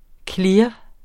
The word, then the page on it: clear: ˈkliɐ̯; ˈkliɐ̯ˀ